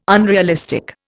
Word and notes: unrealistic: Indian

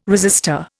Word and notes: resistor: Indian

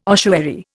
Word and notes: ossuary: Indian

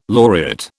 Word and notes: laureate: British English

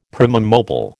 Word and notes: primum mobile: American English

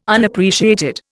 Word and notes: Unappreciated: Indian

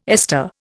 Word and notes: ester: Indian

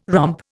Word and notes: romp: Indian

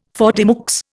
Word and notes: faute de mieux: Indian

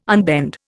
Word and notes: unbend: Indian

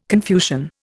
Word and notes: Confucian: Indian

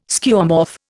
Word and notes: Skeuomorph: Indian

